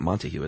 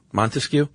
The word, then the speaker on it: Montesquieu: How do you pronounce that? It's not Montague, is it? – Montesquieu.